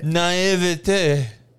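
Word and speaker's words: naïveté: How do you say that word? – So you got it. – Naivete.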